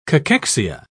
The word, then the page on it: cachexia: British English